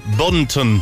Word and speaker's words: Bunton: Bunton.